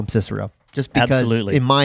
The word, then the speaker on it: Cicero: An ancient world history professor who insisted that you would pronounce it Kikero.